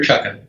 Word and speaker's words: Chukka: And if they look at the same shoe, imagine they look at the Striker, am I pronouncing right, Chuka or Chukka? – The Striker Chukka. – Chukka.